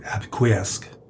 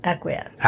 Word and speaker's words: acquiesce: And they were able to acquiesce. Did they say that right? – No. – What is it? – Acquiesce. – Acquiesce. That was close.